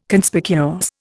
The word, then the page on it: Conspicuous: Indian